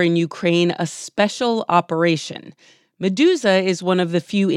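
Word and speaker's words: Meduza: Medusa. Am I pronouncing that right? – Yes.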